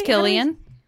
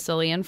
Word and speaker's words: Cillian: What, I think it's Cillian. – Okay. – Even though we all thought it was Cillian for a long time.